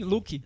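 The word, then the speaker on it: Looke: Aquele streaming, o Look. Não sei se pronuncia assim. Sim, Look. Look. Teve um filme que tá inédito no Brasil, mas teve uma indicação ao Oscar, que é o Loving.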